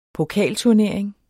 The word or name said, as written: pokalturnering